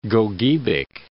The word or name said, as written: Gogebic